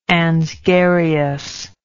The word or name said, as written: ansgarius